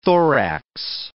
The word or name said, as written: thorax